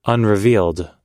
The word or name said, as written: unrevealed